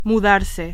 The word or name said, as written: mudarse